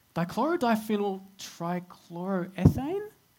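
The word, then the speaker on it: dichlorodiphenyltrichloroethane: DDT or dichloro dichloro dichlorodiphenyl trichloroethane? Did I get that right? – Yeah, you're getting better.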